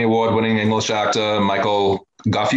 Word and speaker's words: Gough: It's spelled G-O-U-G-H. So would that be Gough? Gough? Gough? – Oh, see, I've been saying that.